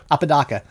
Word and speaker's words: Apodaca: In 1972, APODACA v. Oregon. I don't know. That's how you pronounce it. I'm struggling with that one. – Apodaca. He's from Scappoose. – Apodaca from Scappoose, Oregon.